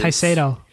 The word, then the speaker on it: Caicedo: Deiber Caicedo, I think. – Uh, yes, is... Casado. Casado. Yeah, from Colombia, correct? – Yes, yeah.